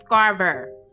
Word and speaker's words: Scarver: Like the S is silent. The S ain't motherfucking silent, bitch. It's Scarver. Scarver stated that he spent 16 years in solitary confinement and the result of only Jeffrey Dahmer's killing.